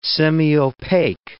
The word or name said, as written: semiopaque